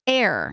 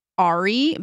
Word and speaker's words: Aire: Now we know that it is Air, and it's spelled A-I-R-E. So some people thought maybe it was Ari, but she said that it's pronounced like Air, and Air means Lion of God.